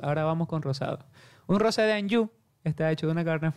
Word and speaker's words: Anjou: Rosé de Anjou se escribe, pero se dice Rosé de Anjou.